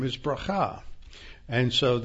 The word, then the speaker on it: bracha: And the Hebrew is pronounced bracha. B-E-R-A-K-A-H would be the correct spelling because it doesn't have a C-H or a chet in there.